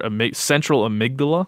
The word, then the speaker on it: amygdala: Forgive me if I butcher this, but it's the central amygdala. Is that right? – Mm-hmm, mm-hmm. Yes, exactly. – Yeah, tell us a little bit about the amygdala and what it does.